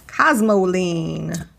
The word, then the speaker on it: Cosmoline: C-O-S-M-O. – C-O-S. – S-M-O. L-I-N-E. – L-I-N-E. Mm-hmm. Cosmoline.